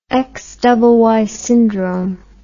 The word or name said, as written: xyy syndrome